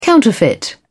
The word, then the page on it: counterfeit: British English